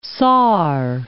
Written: Saar

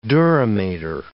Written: dura mater

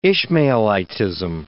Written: Ishmaelitism

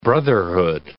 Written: brotherhood

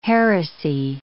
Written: heresy

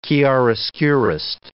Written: chiaroscurist

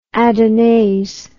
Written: adonais

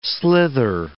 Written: slither

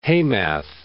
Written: Hamath

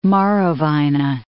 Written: Marowijne

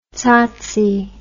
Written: tsotsi

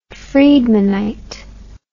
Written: Friedmanite

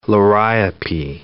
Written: liriope

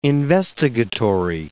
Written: investigatory

